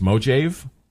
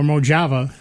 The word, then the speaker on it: Mojave: And that's the first thing, you know, people pronounce it wrong. It's Mojave? – Mojave. – Mojave. – Mojave or Mojava, I heard somebody say that. Mojave? I said, there's not even an A on the end.